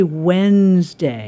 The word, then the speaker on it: Wednesday: So we say Wednesday. – And it's Wednesday. – It's Wednesday. Yeah. Oh, it's Wednesday. – Yeah, yeah, okay. – Wednesday. So the memory trick for Wednesday, because we never pronounce the D, is we do not eat soup on Wednesday. So that gives us the W-E-D.